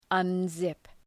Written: unzip

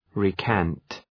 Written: recant

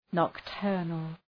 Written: nocturnal

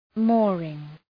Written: mooring

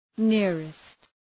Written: nearest

